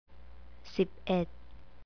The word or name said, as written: siped